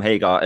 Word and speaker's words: Hagar: It's a weird one to pronounce, but yeah, I am Hagar, as in Hagar the Horrible, the Viking.